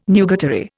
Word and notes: nugatory: Indian

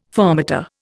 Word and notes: fermata: Indian

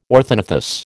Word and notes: orthognathous: American English

